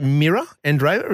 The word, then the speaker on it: Mira Andreeva: Hey, this 16-year-old Russian, is it Mira Andreeva?